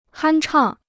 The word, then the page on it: 酣畅: hān chàng